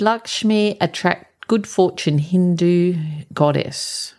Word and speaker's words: Lakshmi: And it says Lakshmi pronounced lock, L O C K S C H M E E. So it's, even though it's spelled, it looks like Lakshmi, it's actually pronounced Lakshmi, is the Hindu goddess of good fortune and beauty.